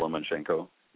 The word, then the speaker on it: Lomachenko: And I believe, is it Lomachenko? Am I pronouncing that right? – Lomachenko.